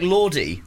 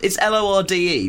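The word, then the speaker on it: Lorde: Is it pronounced? It's L O R D E. The New Zealand guy is very fashionable. – Lord, isn't it?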